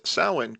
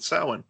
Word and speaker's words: Samhain: he pronounces Samhain correctly in the movie, so props to them. Like, they corrected the mistake of part two and pronounced it correctly. He pronounced it Samhain.